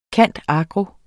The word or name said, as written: cand.agro.